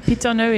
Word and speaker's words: Pitonneux: Yeah, pitonneux is P-I-T-O-N-N-E-U-X. R-O-N-N-E-U-X. And my name, Roberta, R-O-B-E-R-T-A.